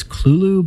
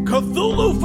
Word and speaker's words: Cthulhu: I think that generally Lovecraft scholars pronounce it as Clulu, but then again, he changed it over time.